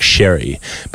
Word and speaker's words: Xerri: Now, I'm going with a Spanish pronunciation here because it's X-E-R-R-I, so I'm going Mark Sherry,